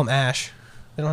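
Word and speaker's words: Æ: It's the symbol, the A, like E thing. Uh-huh. Yeah, but it's pronounced Ash.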